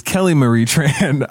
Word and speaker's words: Kelly Marie Tran: It's Kelly Marie Tran.